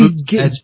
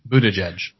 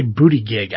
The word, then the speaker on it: Buttigieg: Booty, Buttigieg. – Edge. Buttigieg. Yeah, I keep wanting to say booty gig.